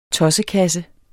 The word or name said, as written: TOSSEKASSE